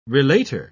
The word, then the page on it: relator: British English